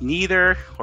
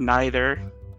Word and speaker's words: neither: It's either pronounced neither or neither and it's both the same way.